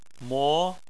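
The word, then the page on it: mo: tone 2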